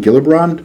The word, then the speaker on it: Gillibrand: Prior to the EU imposing a label on Israeli products in November, Senator from the great state of New York, Kirsten Gillibrand, am I pronouncing that right? – Yes.